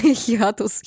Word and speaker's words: хиатус: Да, у, нас, у нас этот хиатус, хитаус. Мы так и не запомнили это чертово ударение. Хиатус.